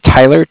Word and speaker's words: Tyler: My name is Tyler, T-Y-L-E-R. Mm-hmm. And I'm calling with Cardiff. It's spelled C-A-R-D-I-F-F, as in Frank. – C-A-R-D-I? – D-I-F-F, as in Frank.